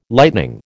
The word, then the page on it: lightning: American English